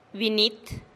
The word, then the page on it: biniit: TdVZ